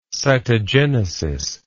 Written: cytogenesis

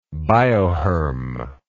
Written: biohermal

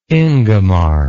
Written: ingemar

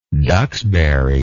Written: duxbury

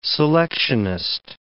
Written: selectionist

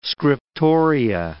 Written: scriptoria

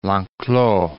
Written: Lenclos

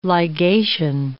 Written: ligation

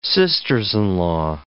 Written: sisters-in-law

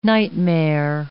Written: nightmare

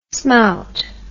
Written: smout

